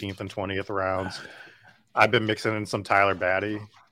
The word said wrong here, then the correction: Batty: How do you know the hell you say his name? – Beatty. Yeah. – Yeah, Beatty. I've been mixing him in a little bit just because we know what that shit.